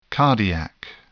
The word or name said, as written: cardiac